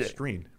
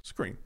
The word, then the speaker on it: Skrine: Skrine, right? – I've heard it both ways. I don't know. It's both.